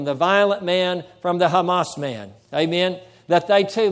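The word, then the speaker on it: Hamas (חָמָס): As we pointed out before, the word violence and the word violent is the Hebrew word, believe it or not, is the Hebrew word Hamas. That's how you say, that's the Hebrew word that's translated violence or violent is pronounced Hamas.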